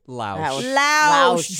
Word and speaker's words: Lausch: Lausch.